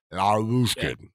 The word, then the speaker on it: Lyubushkin: You just say it quick. You say it like that. Lyubushkin.